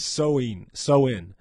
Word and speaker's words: Samhain: Halloween's origins date back to the ancient Celtic festival of Samhain, pronounced, oh, wow. Pronounced Samhain.